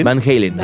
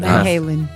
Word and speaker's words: Van Halen: ¿Sí se dice así? Van Halen. – Van Halen. – Van Halen. – Disculpen mi inglés.